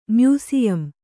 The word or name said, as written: ಮ್ಯೂಸಿಯಂ